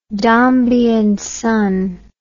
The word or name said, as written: Dombey and Son